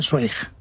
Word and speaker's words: Zweig: Tú sabes que yo toda mi vida he estado diciendo que yo leía Stephen Zweig.